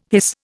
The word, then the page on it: hiss: Indian